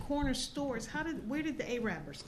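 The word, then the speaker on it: Arabber: The first one is like the A-rappers, because Baltimore's known for the A-rappers, spelled like Arabers, but it's pronounced A-rappers, but it's a Baltimore thing.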